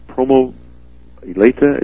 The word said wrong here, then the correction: Loma Prieta: is that the word? The earthquake in 1989 up there in San Francisco. – Loma Prieta, right.